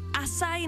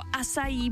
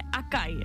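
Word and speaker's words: Açaí: Asaj nebo asají bowl a ne akaj. Někdo říká acai bowl, tak je to acai.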